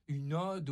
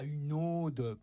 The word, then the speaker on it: ode: Une ode, ODE, ça se dit avec un O ouvert, et pas une ode, comme on entend dans le film, comme le prénom, avec, comme si c'était un O fermé.